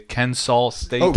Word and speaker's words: Kennesaw: Kennesaw State.